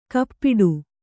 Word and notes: ಕಪ್ಪಿಡು: kappiḍu

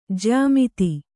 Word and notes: ಜ್ಯಾಮಿತಿ: jyā miti

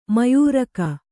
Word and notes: ಮಯೂರಕ: Kannada

